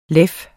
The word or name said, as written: leven